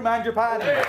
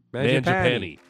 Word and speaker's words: Mangiapane: Have we been saying his name wrong the whole time? Because his dad just said his name. – 6'1", 210 pounds, number 88, from Bolton, Ontario, Andrew Mangiapane. – Mangiapane.